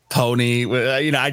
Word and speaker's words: Ponyc: And I'm a solo artist, but I'm also part of a group that just dropped an album called Pony. It's spelled P-O-N-Y-C. The C is silent.